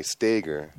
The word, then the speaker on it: Steger: No, A, long A, Steger.